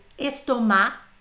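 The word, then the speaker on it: estomac: Não pronunciamos o C, principalmente das que terminam com A, C, com A, C, tá? Não são muitas, mas as mais usadas eu vou colocar aqui para vocês. Por exemplo, estomar, não falo C.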